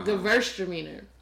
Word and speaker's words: Gewürztraminer: The W is like a V sound. Uh-huh. The Gewürztraminer.